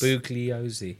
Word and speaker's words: Bugliosi: Because Vincent, the lawyer called Vincent Bugliosi. – Yeah. – I think I pronounced that wrong. – What? – He was... – Bugliosi. Yeah, that's right.